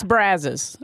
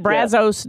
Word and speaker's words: Brazos: You need to work on that Brazos pronunciation. It's Brazos, okay? – Yes. – That Brazos doesn't cut it.